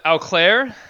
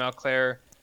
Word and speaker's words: Auclair: Number four is Adam Auclair.